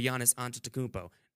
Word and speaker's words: Giannis Antetokounmpo: Albert Einstein, if he was still alive today, would not have an answer for Giannis Antetokounmpo.